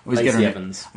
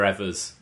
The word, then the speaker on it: Evans: Lacey Evans. – I always get her name wrong. – You always call her Evers.